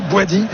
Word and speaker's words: Bouadi: Je me disais c'est qui Ayoubi, je regardais ma fiche, je me dis c'est ça, j'avais besoin d'un coup de brumisateur. – Il manquait un syllabe au milieu quoi.